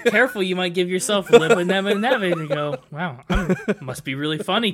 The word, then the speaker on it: lipomyelomeningocele: It says right here, pronounced lipo, L-I-P-O, my, E-L-O, which I'm pronouncing as L-O, min, in, go, seal. So lipomyelomeningocele.